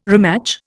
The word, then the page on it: rematch: Indian